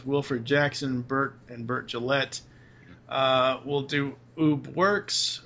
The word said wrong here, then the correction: Ub Iwerks: how do you say his name? – I think it's Ub Iwerks. – Ub Iwerks. Okay, there you go.